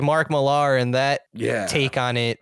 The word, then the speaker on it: Millar: Whoa, is that how you pronounce it? Millar? I thought it was Mark Miller. – I thought it was Mark Miller too. – For the longest time I did, but it's spelt M-I-L-L-A-R. – I know, but it's, I feel like things are still like. – He's also like, I think he's Scottish or British. – Pillar is spelled P-I-L-L-A-R.